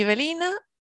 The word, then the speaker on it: Ivelina: Y pues yo soy Belina.